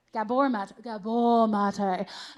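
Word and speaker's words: Gabor Maté: Gaber Mate? I don't know how to say his name. Gaber Mate? Gabor Mate.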